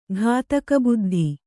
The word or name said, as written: ಘಾತಕಬುದ್ಧಿ